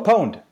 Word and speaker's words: pwned: It's like P W O N D. It's like owned, but pwned. – Oh, pwned.